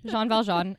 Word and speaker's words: Jean Valjean: So he's trying to portray himself as Jean Valjean. Am I saying that right? – Yeah. – Yep. Jean Valjean as a rebellious protagonist.